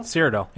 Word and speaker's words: Cyrodiil: Cyrodiil, yeah.